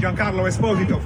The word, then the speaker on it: Giancarlo Esposito: Giancarlo Esposito from Italia. – Esposito. – Oh, stop playing with him. Damn. – Giancarlo Esposito.